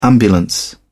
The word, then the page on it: ambulance: British English